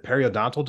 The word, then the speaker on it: periodontal: What are some of the health effects that might come from periodontal disease? Am I pronouncing that correctly? – Yeah.